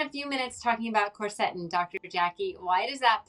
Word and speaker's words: quercetin: I'll take quercetin, but the way I pronounce it is quercetin. – Quercetin, got it. You're just putting the emphasis on a different syllable.